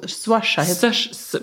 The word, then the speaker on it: Saoirse: Svårt uttala det här namnet tycker jag. – Jag vet, jag googlar. – Saoirse med kort ö. – Aha, Saoirse. – Ja, något sånt.